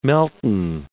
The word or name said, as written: melton